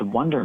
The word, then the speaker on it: WNDR: And it is pronounced Wonder Museum. So it is true that you are not as familiar with it. The acronym, it looks like an acronym, WNDR Museum.